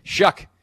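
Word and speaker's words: Shough: I knew it was a shuh sound, and I literally, that was just stuck on the tip of my head. Well, and it's not spelled like shuck either.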